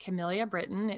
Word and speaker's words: Kamelia Britton: So I'm on Instagram at Kamelia Britton.